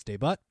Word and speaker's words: debut: New Year's Hat Hoot Hoot and 2022 Glasses Slowking make their debut. Sorry, I'm pronouncing that incorrectly. It's Daybut.